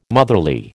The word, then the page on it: motherly: American English